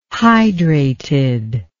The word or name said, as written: hydrated